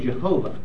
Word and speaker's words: Jehovah: they don't have that sound in classical Latin. They pronounce it as a J. And they don't pronounce a W in medieval Latin. The W sound they pronounce like a V. So that name became Jehovah.